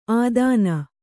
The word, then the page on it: ಆದಾನ: ādāna